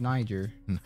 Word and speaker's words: Niger: They say I'm saying it wrong, but I see it as Niger. – It's Niger.